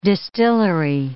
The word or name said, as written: distillery